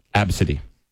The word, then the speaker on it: ABCDE: They pronounced the kid's name as Absidy.